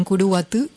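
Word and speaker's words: Curuguaty: Una página con respecto a lo que sucedió en la masacre es ¿qué pasó en Curuguatú? Con Y al final, así se pronuncia en paraguayo, .org creo.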